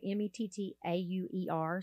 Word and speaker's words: Mettauer: M E T T A U E R.